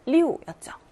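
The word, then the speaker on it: 六: 숫자 6의 발음은 리우였죠. 리우, 사성입니다.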